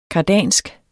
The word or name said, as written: kardansk